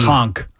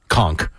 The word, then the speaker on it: Conk: M-E-T-E-D. – Conk. – Conk. I didn't say cock. – Yeah. Right. Conk.